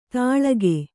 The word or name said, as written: ತಾಳಗೆ